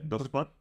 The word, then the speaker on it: Dusknoir: Es Dusknor. – Ah, nice. Muy bien. – ¿Se dice Dusknor? Pues yo lo pronuncio así también. Dusknoir. Porque hablo francés.